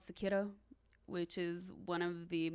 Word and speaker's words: Sekiro: Oh, it's Sekiro. – Yeah. – S-E-K-I-R-O.